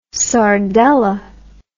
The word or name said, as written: sardelle